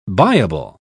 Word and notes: buyable: British English

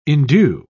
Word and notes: indue: British English

